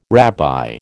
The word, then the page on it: rabbi: American English